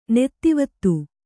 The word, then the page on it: ನೆತ್ತಿವತ್ತು: Kannada